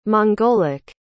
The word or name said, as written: mongolic